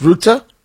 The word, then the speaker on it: Rutte: Then President Trump, amongst a series of things being discussed, he's sitting with Mark Rutte, sometimes pronounced Rutte.